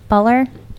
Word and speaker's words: Buhler: Now, this was written by, the screenplay was written by two people, Matt Greenberg and Jeff Bueller. I don't know how to say it. – Bueller. – Jeff, B-U-H-L-E-R. Buller. Jeff Buller.